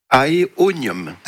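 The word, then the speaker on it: Aeonium: Alors, Michel, elle a un aénomium. Ça se prononce comme ça? C'est ça? – Aéonium. – Aéonium, très bien, qui semble végéter.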